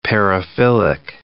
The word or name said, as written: paraphilic